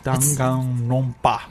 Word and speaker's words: Danganronpa: So my girlfriend's really into Danganronpa? – Yeah. – I always say it wrong, so I have to really focus when I say it. – I have to, too. – Yeah. – Danganronpa.